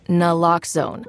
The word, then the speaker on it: Naloxone: For the record, it's Naloxone. Nuh, like, is Naloxone addictive? Nuh-uh. Lox, like, Naloxone keeps humans safe, like locks keep bikes safe.